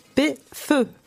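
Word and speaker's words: Phở: Comment comment c'est la traduction Comment on l'écrit PH PHO, mais ça se prononce P feu. – comme pot-au-feu.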